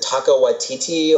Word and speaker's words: Taika Waititi: Is it Taika Waititi or? – I think you pronounce it correctly.